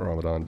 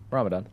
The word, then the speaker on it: Ramadan: And he was talking about what's the fasting period, not Ramadan, but Ramadan. – That's yeah. what it was. And he was talking about Ramadan